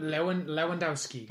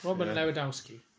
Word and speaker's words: Lewandowski: Robert Lewandowski. – That's it. It's not far off. – Lewandowski.